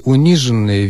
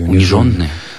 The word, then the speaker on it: униженные: Вот, да нет, ну, видимо, причастие униженные, видимо, у кого-то возникло подозрение, что оно должно произноситься с ударением на суффиксе. – Униженные? – Но это, конечно, неверно. Только так, конечно. Униженные и оскорбленные.